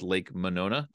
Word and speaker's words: Monona: and you guys would have to correct me on my pronunciation here, Lake Mendota and Lake Monona. Did I get those right? – Yep, nailed it.